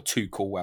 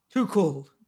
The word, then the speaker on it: Tuchel: I could see him going for Tuchel. I could see that link happening. Indeed. Or Tuchel, however you want to pronounce it. – Tuchel. There we go.